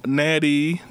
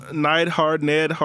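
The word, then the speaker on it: Neidhart: Natalia Nathart. – Nighthart.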